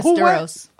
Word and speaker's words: Westeros: It's Welcome Back to Westeros. – Well, Westeros.